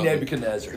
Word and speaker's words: Nebuchadnezzar: I said King Shishka. It's King Nebuchadnezzar. – There you go, Team Nebuchadnezzar. So I'm finally saying it right.